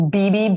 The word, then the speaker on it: Bibi: That's why it's Bibi, B-I-B-I.